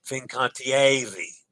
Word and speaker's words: Fincantieri: Fincantiari, ari, not ari. Fincantiari, ari, Fincantieri. Ooh, yes. I did I did pronounce that syllable incorrectly.